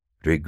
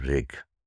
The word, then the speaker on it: Rig: Rig Veda, which is encyclopedic Veda, R-I-G is Rig. Sometimes it's spelled R-G, Rig. Sometimes it's spelled R-K, Rig, because it's a kind of a closed sound, Rig, and it's a little bit indistinguishable as to whether it's a V, a G, or a K.